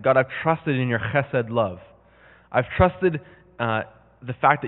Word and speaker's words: chesed: The word is chesed. It's spelled C-phlegm-E-S-E-D. And the Bible talks about chesed love. It's a Hebrew word.